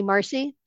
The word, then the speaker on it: Marci: My name is spelled M-A-R-C-I, so Money Marcy, spelled that way, .com.